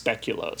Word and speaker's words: speculoos: According to Google, it's speculoos. – Speculoos. Weird. It doesn't look like that. But also, whatever. Okay, so that feels like a bastardization of the spelling. – Speculoos. – Like it dinged that I'm from North America, and it's like, it's pronounced speculoos.